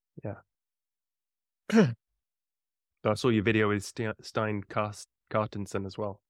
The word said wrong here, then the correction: Stian Carstensen: How do you say it? Yeah. Stian Carstensen.